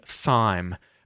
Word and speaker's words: thyme: So, it looks like it could be thyme, but it's actually a silent H. Well, the H in it is not pronounced as an H, nor does it cause the T-H to be pronounced as a th sound or a v sound, right? A T-H sound. So, it's pronounced as thyme. And thyme, T-H-Y-M-E, is a low-growing aromatic plant of the mint family.